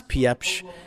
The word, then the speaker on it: Piepsch: It's Piepsch. It's like a French name, and Eli.